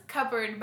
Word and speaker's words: cupboard: Aww, and someone, my friend, he was making fun of me, but he was like, it's pronounced cupboard, by the way. And I'm like, it's not spelled with a B. It's clearly a P.